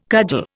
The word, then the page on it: cudgel: Indian